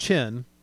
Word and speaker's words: Qin: So in the kingdom of Qin, pronounced Qin, so it's Q-I-N, but it's pronounced Qin, which is where we get the word China from, they found out that there was this kingdom going on.